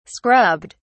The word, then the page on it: scrubbed: British English